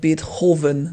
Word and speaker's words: Beethoven: Der Russe wüsste mit Beethoven nichts anzufangen, aber mit Beethoven natürlich. Das wird etwas anders ausgesprochen.